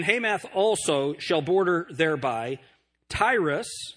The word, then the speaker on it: Tyre: Tyre and Sidon, S-I-D-O-N, that's the way that we would pronounce it now. T-Y-R-E, Tyre and Sidon, those are the two cities that are being spoken of here.